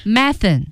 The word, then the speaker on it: methane: Methane. – Yeah. – 啊，用的少。Methane. – Yeah. Methane. – Methane 沼气。 – Yeah. – 甲烷。 – British people say methane, and Americans say methane. So two、oh, pronunciations for that.